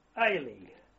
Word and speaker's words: Aley: But subsequently, I was given permission to take a leave party without arms to a place called Ailey, which is spelled A-L-E-Y. At the time, the pronunciation, as I heard it, suggested to me that the place I'd been given leave to was Zahle, which is Z-A-H-L-E.